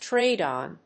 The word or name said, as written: trade on